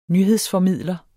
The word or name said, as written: nyhedsformidler